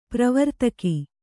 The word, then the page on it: ಪ್ರವರ್ತಕ: pravartaka